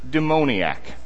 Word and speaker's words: demoniac: And then we move into this section here, where Jesus has this encounter with the Gerasene demoniac. That is I got a ruling from Provost Rick Reed over here. He says demoniac is the preferred pronunciation.